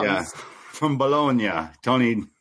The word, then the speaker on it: Bologna: So this Friday, they're going to be in Italy, Bologna.